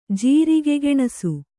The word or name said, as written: ಜೀರಿಗೆಗೆಣಸು